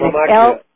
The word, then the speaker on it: Lamacchia: And, Joe, your last name, you pronounce it Lamacchia. And Lamacchia. – It's L-A-M-A-C-C-H-I-A. L-A-M-A-C-C-H-I-A.